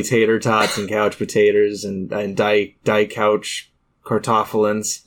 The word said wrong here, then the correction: Kartoffeln: Yeah, I was going to correct you on the pronunciation, then I remembered I failed German. So, yeah. – D couch Kartoffeln.